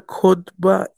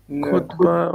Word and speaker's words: khutbah: You know what a khutbah is? – Actually, no. – Khutbah or something like you go to the mosque and then the guy's talking before the prayer, right? – You're saying it right.